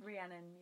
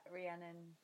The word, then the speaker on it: Rhiannon: I think I actually mispronounce my own name when Welsh people say it. It's a Welsh name. When Welsh people say it, it sounds very different. – Rhiannon, actually.